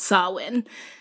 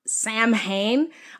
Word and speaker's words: Samhain: So the foundation of Halloween can be traced back over 2,000 years to a Celtic pagan holiday called Samhain. So it's spelled Samhain,